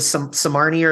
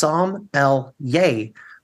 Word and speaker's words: Sommelier: It was my license plate because I wanted a vanity plate and I got so tired of people mispronouncing it. Oh, you're the Somarnier, or the Somalian, the Somarnier, my favorite one, or Sommelier. And I was like, it's Somlyay.